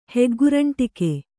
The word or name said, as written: ಹೆಗ್ಗುರಂಟಿಕೆ